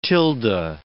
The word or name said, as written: tilde